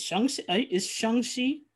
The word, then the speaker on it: Shang-Chi: Is Shang-Chi? – That is how Kevin Feige pronounces it.